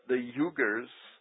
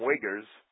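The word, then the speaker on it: Uighur: The Uyghurs, I like to call them Uyghurs, but they're the Uyghur people. That's U-I-G-H-U-R.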